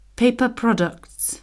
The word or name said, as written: paper products